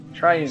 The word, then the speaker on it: Triune: And so, basically, the season 18 is going to be called the season of Triune. I'm saying that right? Triune. – Said it right.